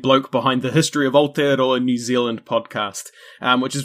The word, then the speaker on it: Aotearoa: It is me and Christian here with our very good buddy, Thomas Realstone from the History of Aotearoa.